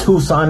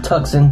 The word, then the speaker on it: Tucson: I'm in Tucson, Arizona, by the way. It's pronounced Tucson, not Tucson, thank you.